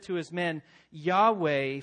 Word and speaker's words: Yahweh: And we best understand how to pronounce it, I believe, as Yahweh.